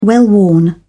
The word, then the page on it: well-worn: British English